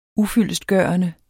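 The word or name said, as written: ufyldestgørende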